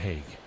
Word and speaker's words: Hague: And when he was coming up, his name is spelled H-A-G-U-E, Matt Haig.